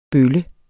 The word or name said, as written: bølle